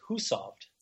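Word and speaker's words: Hushovd: You know, I mean, Fabian Cancellara, Tor Husov, actually, by the way, it is called, we don't pronounce his name properly. It's Husovd, but Husovd. We all pronounce it Husovd. And I have not made the change yet in broadcast because I don't want everybody to start writing in saying I'm doing it wrong. they don't pronounce it SH like we do in the States. It's Husovd.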